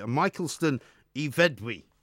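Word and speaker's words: Michaelston-y-Fedw: Michelston Iveddwy. – That sounded very good. – Right? Because apparently the Y, the W is like a Y and the F is like a V.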